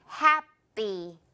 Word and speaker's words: happy: 그래서 어떻게 소리 난다? happy. happy? p 발음 그렇게 나면 좀 어색해요. happy.